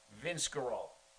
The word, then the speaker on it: Vince Guaraldi: Vince Guaraldi.